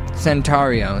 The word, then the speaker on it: Santuario: How do you say that? Santuario? – No, man, you're doing a good job. – How do you say it? Where do you go? Santuario.